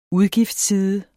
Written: UDGIFTSSIDE

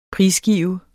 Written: prisgive

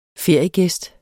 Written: feriegæst

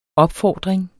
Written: opfordring

